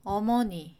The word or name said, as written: 어머니